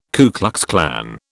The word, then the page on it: Ku Klux Klan: British English